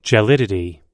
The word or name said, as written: gelidity